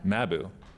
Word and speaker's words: Mabou: She's from Mabou. Is that how you say it? Mabou, Cape Breton. – Mabou? – Mabou.